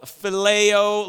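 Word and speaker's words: phileo (φιλέω): So the first type of love I want to talk about is called phileo love. Everybody say phileo fish. Okay, if you now you know how to say it.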